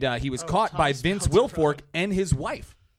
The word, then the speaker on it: Wilfork: Wilfork.